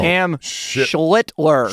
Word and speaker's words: Schlittler: Cam Schlittler.